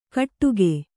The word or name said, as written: ಕಟ್ಟುಗೆ